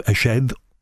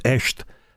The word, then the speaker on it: Eshed: What about the revelations in the last week or so from the former head of the Israeli space program, Chaim Eshed, or I believe it's pronounced Esht, who says that effectively we've been working with alien civilizations.